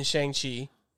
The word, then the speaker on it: Shang-Chi: Yeah, so Wong is going to be in Shang-Chi.